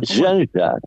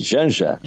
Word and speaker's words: Janja: No sé cómo se dice, Yanja, Janja. – Yanja, sí, Yanja, exactamente.